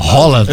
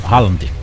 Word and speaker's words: Haaland: Haaland. – Eu tô falando Haaland. Com A. Com A, mas você sabe que é com O, né? – Haaland.